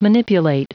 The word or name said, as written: manipulate